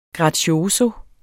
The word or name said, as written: grazioso